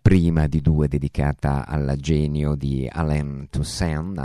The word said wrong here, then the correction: Toussaint: anzi Toussaint, la pronuncia appunto alla francese,